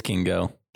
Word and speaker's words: Kingo: Yeah, am I saying that right, Kingo? I can't remember how they were. – Yeah, it's Kingo.